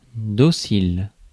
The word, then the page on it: docile: American English; IPA: /ˈdɑː.səl/; IPA: /ˈdɑː.saɪl/